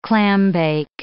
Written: clambake

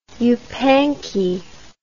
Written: yupanqui

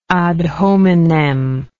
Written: ad hominem

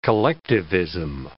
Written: collectivism

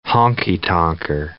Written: honky-tonker

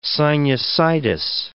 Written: sinusitis

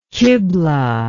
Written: kiblah

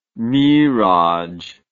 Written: miraj

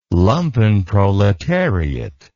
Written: lumpenproletariat

